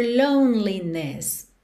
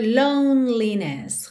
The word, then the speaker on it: loneliness: Of your loneliness, loneliness. Então, percebe que esse E do meio não é pronunciado. Você pensa em lone, parei aqui no N, já sigo pro L.